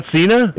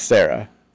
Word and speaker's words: Cera: It's Sarah. – Sarah? Yeah. Like the name? – Yeah. Well, C-E-R-A. – Yeah, I mean, I know he's supposed to be with a C, but it's pronounced Sarah. I always thought it was pronounced Cena.